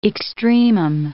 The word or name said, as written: extremum